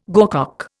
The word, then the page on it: gorcock: Indian